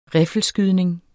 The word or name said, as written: riffelskydning